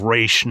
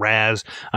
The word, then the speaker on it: Ra's: It's phonetically spells it out here, the version of Ra's, not Raz.